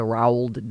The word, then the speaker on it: Roald: I wasn't able to find a price for what they paid for all the rights to Roald Dahl's. How do you say his name? It's like Ronald without an N in it. Roald.